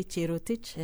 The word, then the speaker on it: Jerotich: Well, in Calais, you don't really say Jerotich or Jerotich. You say Jerotich.